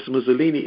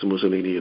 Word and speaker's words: Mizulina: And that's, of course, Mussolina is spelled M-I-Z-U-L-I-N-A. So let me read that sentence again, because I don't want you to think it's Mussolini of the past.